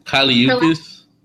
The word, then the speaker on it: Kali Uchis: Kali Uchis. – Uchis. Okay, I always see these people's names on like the internet, so I'm only reading them and I never say it out loud. So Kali Uchis versus Selena Gomez.